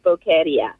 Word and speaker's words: Boqueria: In fact, I phoned them last night just to double check that that's how you say it. But yes, Boqueria.